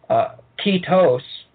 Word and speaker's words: Ketos: and in Greek it's Cetus. – Right. – Technically that's a modern pronunciation, Ketos, which is closer to what it says, but it's a monster.